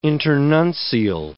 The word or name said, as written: internuncial